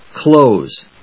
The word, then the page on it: close: klóʊs; klˈəʊs